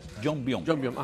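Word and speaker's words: Yongbyon: ¿Es así se pronuncia? Jongbyon, ajá. – Exacto.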